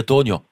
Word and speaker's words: Tonha: C'est un nom de famille qui correspond sans doute à l'occitan Tonia, T-O-N-H-A, qui signifie le pain noir ou le pain de seigle. – Alors je vais vous corriger sur la prononciation, si Jérôme Delbès était là, il dirait Tonia. Faut le A un peu ouvert, comme Occitania, vous dites Occitania, on va dire en occitan.